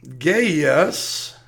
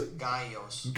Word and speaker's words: Gaius: And then we hear about Gaius, and let's make sure we're saying his name right. Gaius, we're going to go to him in the Bible as well. He's Gaius.